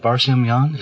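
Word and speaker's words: Barsamian: And he appeared on alternative radio with David Barsamyan.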